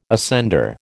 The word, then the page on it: ascender: American English